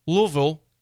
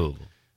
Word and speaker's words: Louisville: I say Louisville. It's not Louisville.